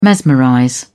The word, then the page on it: mesmerize: British English